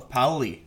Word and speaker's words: Paoli: Today, I am joined by the one and only Carl Paoli.